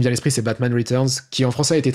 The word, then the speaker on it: Riteurnz: mais ça s'écrit R-I-T-E-U-R-N-Z, donc Returns, vraiment.